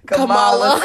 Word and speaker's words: Kamala: Kamala will glow up. – Look how you say her name. Everybody got to think about how to say her name. We want to call that lady Kamala.